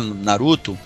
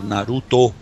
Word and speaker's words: Naruto: você fala Naruto, mas é Naruto. Naruto. Né? É Naruto, é, exatamente. É, o O vira U, né? Assim como o E vira I, né?